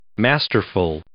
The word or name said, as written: masterful